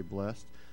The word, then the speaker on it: blessed: Okay, so when it's past tense, it's one syllable, like we were blessed by his presence. Okay, that's when you say blessed.